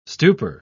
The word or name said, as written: stupor